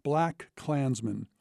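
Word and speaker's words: BlacKkKlansman: And it's the latter that is at the heart of his latest film, which is pronounced, by producer Jordan Peele at least, the way the book it was adapted from is spelled, Black Klansman.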